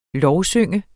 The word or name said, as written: lovsynge